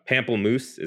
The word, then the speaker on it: pamplemousse: It's the uh, pamplemousse. Is that how you say it? Uh, yep.